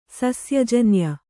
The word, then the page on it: ಸಸ್ಯಜನ್ಯ: sasya janya